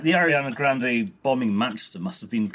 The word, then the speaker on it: Ariana Grande: Ariana Grande.